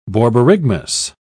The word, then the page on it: borborygmus: British English